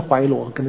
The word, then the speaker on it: Philo: 这个 philo 这个，如果各位有机会的话，其实有一些可能英文的翻译一定有这个 philo 啊，或者我们中文翻的怀罗，可能是 philo 有英文发音呢、啊。因为我是在欧洲学，我就用那个欧洲语言发音 philo。